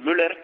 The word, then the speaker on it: Müller: Markus Müller, no sé si es Müller o Mahler, no sé cómo, si lo estoy pronunciando bien tu nombre. – Sí, más o menos, hombre, se dice Müller, pero aquí en España con Müller está bien, como el jugador de fútbol.